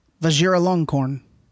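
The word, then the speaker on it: Vajiralongkorn: Vajira Longkorn.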